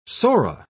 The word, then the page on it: psora: British English